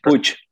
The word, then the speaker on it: Puig: I remember it because it created a lot of buzz, kind of similar with what happened with Ricky Puig. Forgive me if I said it wrong again. – Puig. – I should know that.